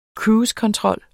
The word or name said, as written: controller